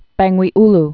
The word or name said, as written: Lake Bangweulu